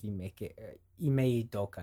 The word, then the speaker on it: Ime Udoka: It People were questioning why they went with the choice of Ime Udoka. I think that's how you pronounce his name.